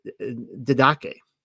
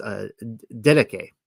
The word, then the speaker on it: Didache: I want to say didache, but I think it's dedike. I think it's dedike, actually. Like, it should be a K there instead of a CH.